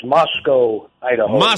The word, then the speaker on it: Moscow: Yes, Mike, but it rhymes with Idaho, so it's Moscow, Idaho.